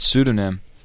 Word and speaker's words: pseudonym: And can I remind you that pseudonym has a silent P? – Really?